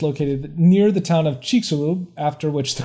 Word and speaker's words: Chicxulub: I think it is pronounced Chicxulub. Oh, wait, Chicxulub. Yeah, that's it, because this is a long E.